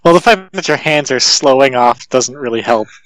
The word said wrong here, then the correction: sloughing: Is that how you pronounce that? – No, it's sloughing.